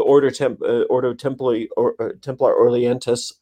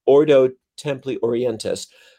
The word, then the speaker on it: Ordo Templi Orientis: And Thelema was actually predated Aleister Crowley, but he kind of co-opted it, just like he co-opted the OTO, the Ordo Templi Orientis. Oh, I said it wrong. The OTO, Ordo Templi Orientis.